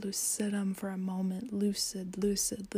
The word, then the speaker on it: lucidum: And I want to pause for a moment and just talk about that second word, lucidium, or lucidum, actually, is probably how it's pronounced.